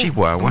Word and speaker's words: Chihuahua: Resulta que nació allá en Camargo, Chihuahua, se nota mi pronunciación, a ver, sí, sí. Chihuahua, Muy bien.